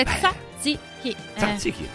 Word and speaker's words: tzatziki: Forse sono riuscito adesso, tzatziki. – È facile, tzatziki.